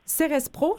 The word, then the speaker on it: Cerespro: Vous êtes enseignante de formation, auteure et fondatrice de Cerespro, je le prononce comme il faut? Oui, exactement.